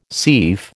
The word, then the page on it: seif: American English